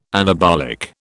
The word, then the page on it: anabolic: British English